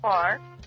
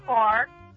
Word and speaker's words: Clark: Clark. – What is it? I'm sorry? – Clark. – Can you spell that? I can't. – C-L-A-R-K.